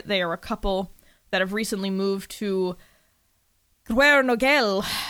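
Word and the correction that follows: Gwernogle: no, Guernogle. That's how you say it. Yes, Welsh is fucking hard.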